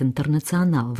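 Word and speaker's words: Internacionál: По-английски она произносится как «International», ну а по-чешски ей говорят «Интернационал» в районе Дэвиц.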